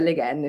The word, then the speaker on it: Le Guin: Ho sentito spesso anche Neil Gaiman, nell'introduzione al discorso che dicevamo prima del 2014, chiamarla Le Guin, quindi io continuerò, penso, per sempre a chiamarla Le Guin. Però credo che la pronuncia esatta sia Le Guin, effettivamente, in francese.